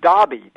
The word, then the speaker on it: Enroughty: G-R-A-I-N-G-E-R. Now, Fisher says that there's a town in Virginia where the word E-N-R-O-U-G-H-T-Y, looks like Inruti, is pronounced Dobby, D-A-H-B-Y.